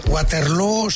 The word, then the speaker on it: Waterloo: Bueno, lo primero, Waterloo se pronuncia igual que Feijó, o sea, es exactamente igual. – ¿Eh? – O Feijó irá a Waterloo, o Feijó irá a Waterloo. Pero los dos son exactamente igual.